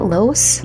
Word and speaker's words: Laos: Now, they immigrated from Laos. Is that how you say it? Laos? – Yeah, Laos.